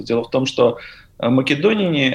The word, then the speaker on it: македоняне: Ну, наверное, правильнее будет ударение все-таки поставить э, македонине.